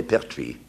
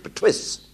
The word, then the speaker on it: Perthuis: And nobody could pronounce the name properly, Pertuis, Pertuis de Layevaux, P-E-R-T-H-U-I-S. They couldn't pronounce it, so the illiterate English pronounced it Pertwis. So it was duly changed to P-E-R-T-W-E.